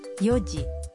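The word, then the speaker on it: یو جی (四時): چار بجے سات بجے اور نو بجے کا تلفظ کچھ مختلف ہے اس لیے احتیاط کیجیے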